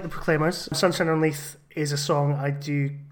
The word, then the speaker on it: Leith: Is it Leith? Leith? How do I say it? – Oh, God almighty. I'm glad I'm here. – Yes. – Sunshine on Leith.